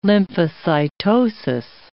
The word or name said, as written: lymphocytosis